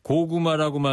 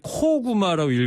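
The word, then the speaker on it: Koguma: 고구마라고 말씀드렸는데 코구마라고 읽어야 된다네요. 작을 소자를 코라고 발음한 되네. 웅자는 고문 구마. 아까 제가 말씀드린 대로 그거고요. 코구마. 고구마가 아니고 코구마로 읽어야 된다.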